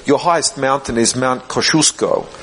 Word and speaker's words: Kosciuszko: Gdybyście przyjechali do Australii i powiedzieli, wasza najwyższa góra nazywa się Kościuszko, no Australian would believe you. – To żaden Australijczyk by wam nie uwierzył. – Because we pronounce it Kosciuszko.